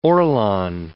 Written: Orlon

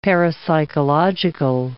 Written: parapsychological

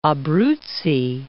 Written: Abruzzi

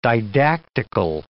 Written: didactical

